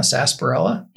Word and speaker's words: sarsaparilla: A sarsaparilla? – Yeah. – Okay. – It says S-A-R. – Yeah, the R is silent.